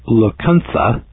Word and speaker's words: Lacunza: Well, here's the story about Immanuel Lecunthe, spelled L-A-C-U-N-Z-A, but pronounced T-H-A, and the book that he wrote.